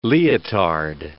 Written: leotard

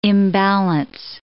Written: imbalance

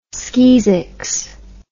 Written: skeezicks

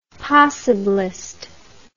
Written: possibilist

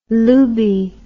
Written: lubie

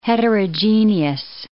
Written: heterogeneous

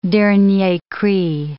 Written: dernier cri

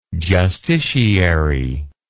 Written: justiciary

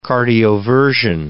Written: cardioversion

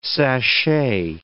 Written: sachet

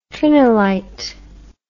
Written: trinilite

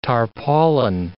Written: tarpaulin

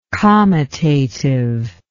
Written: comitative

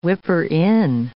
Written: whipper-in

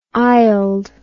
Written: aisled

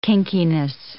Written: kinkiness

